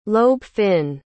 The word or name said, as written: lobe-fin